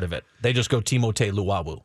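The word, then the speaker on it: Timothé: Timotei is how you pronounce his first name.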